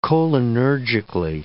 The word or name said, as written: cholinergically